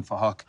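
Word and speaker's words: Phuc: His name is Fahok. We're spelled with a PH, so it sounds like Fahok.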